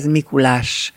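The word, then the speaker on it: Mikuláss: A szervezők a nevüket két S-sel írják, kiejtve ez Mikulás.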